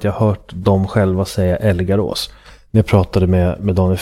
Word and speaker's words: Älgarås: en gammal fabrik eller något sånt där i Älgarås, eller Älgarås, lite osäker på hur man uttalar det, i Töreboda.